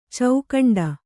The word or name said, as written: ಚೌಖಂಡ